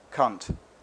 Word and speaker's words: Kant: We stared at one another whenever he said, with emphasis, cunt, which was, he assured us, the correct English pronunciation for the philosopher, Immanuel Kant.